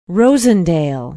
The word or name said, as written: Rosendale